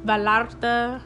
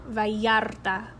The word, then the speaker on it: Vallarta: I went to Puerto Vallarta, Mexico Vallarta is spelled with two L's. Again, that's another example of how it's that y sound. So it's not Puerto, it's not Puerto Vallarta, it's Puerto Vallarta.